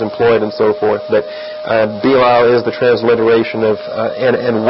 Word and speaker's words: Belial: If you give the Hebrew letters their equivalent, you have B-E-L-I-Y-Y, you got to double the Y, A apostrophe A-L, and you would pronounce it Belial, Belial. But it just generally transliterates across Belial, B-E-L-I-A-L.